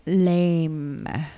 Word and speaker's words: Laime: Wait, is it totally lame or totally Laime? – Laime.